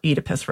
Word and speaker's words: Oedipus: So, for instance, this summer I read Sophocles' Oedipus Rex, or Oedipus Rex is the way all the British classicists pronounce it.